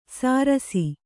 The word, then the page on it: ಸಾರಸಿ: sārasi